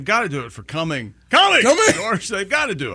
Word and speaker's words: Cumming: I want to go back up to Georgia, because you mentioned earlier the city of Cumming, Georgia, and it's pronounced Cumming.